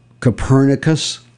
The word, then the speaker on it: Copernicus: Copernicus.